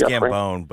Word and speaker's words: Gambon: Dumbledore, Michael Gambon, is that how you pronounce his last name? – I think it's Gambone.